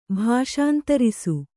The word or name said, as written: ಭಾಷಾಂತರಿಸು